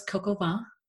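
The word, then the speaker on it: coq au vin: But we chose Coco Vin. If I pronounce that right, Christy? – Oh, très bien. We can say it with American accents. – I do. Well, I'm from the Midwest. – You said it very good.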